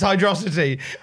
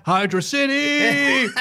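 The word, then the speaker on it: Hydrocity: Kurt Angle genuinely believes it's pronounced Hydrosity. Angle slapped to Naka. – Hydro City. Here comes the city. City, city, city, city, city, city, city. It's pronounced city.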